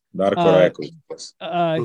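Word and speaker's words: Rajaković: Darko Rajakovic. Am I saying that right? – Yes, Darko Rajakovic.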